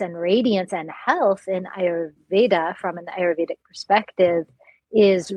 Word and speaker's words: Ayurveda: Tell us about the world of Ayurveda, if I'm even saying it right, and what it all means. – So Ayur means life, and Veda means knowledge or teaching or science.